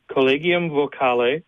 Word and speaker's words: Collegium Vocale: And I even made my phonetic notes, but I know I mispronounced it. The name of the Collegium? – Collegium Vocale. – Vocale. Very good.